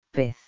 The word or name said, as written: pez